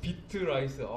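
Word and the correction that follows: Beatrice: 아, 이게 박자 감각 있는 쌀인가 보다. 이렇게 생각하고 얘기했는데 나중에 저한테 이렇게 얘기를 해주시더라고요. 베아트리체라는 곡이라고. 예. 죄송해요.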